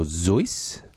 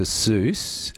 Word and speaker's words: Seuss: Which, so what would the actual correct pronunciation, from what I understand, is Dr. Seuss or Zeuss, but... He got around to people calling him Dr. Seuss because it rhymed with Mother Goose.